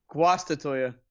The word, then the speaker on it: Guastatoya: Is it Guastatoya? – Guastatoya. – Guastatoya. Thank you.